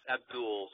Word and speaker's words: Abdel: His name is spelled Abdel. It's Abdul. So just pretend like there's a U there. It's still Abdul.